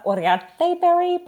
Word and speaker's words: Uriarte-Berry: You did the anglicized version. It's actually Sarah Uriarte-Berry, but I'm not going to hold that against you.